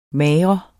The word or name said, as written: mager